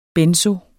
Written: benzoe

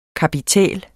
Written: kapitæl

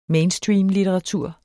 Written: mainstreamlitteratur

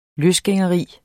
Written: løsgængeri

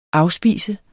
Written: afspise

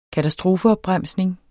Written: katastrofeopbremsning

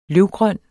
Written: løvgrøn

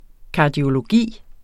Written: kardiologi

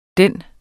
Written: den.

